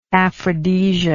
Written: aphrodisia

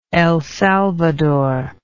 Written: El Salvador

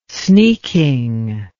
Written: sneaking

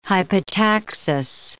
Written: hypotaxis

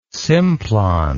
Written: simplon

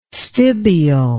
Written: stibial

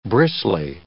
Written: bristly

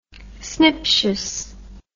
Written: sniptious

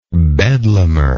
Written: bedlamer

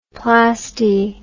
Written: plasty

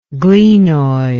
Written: glenoid

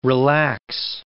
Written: relax

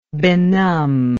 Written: benumbment

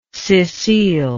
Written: Cecile

